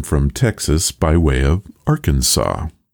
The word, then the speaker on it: Arkansas: In 1881, the Arkansas Historical Society concluded that both Kansas and Arkansas have roots in similar Indian tribe names, but that Kansas chose to follow the standard English pronunciation marked by the hard A sound in can and vocalizing the final S, while Arkansas stuck with the original French pronunciation, which kept that final S silent.